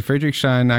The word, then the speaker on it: Friedrichshain: We spent a couple of months here in Friedrichshain, actually, starting there.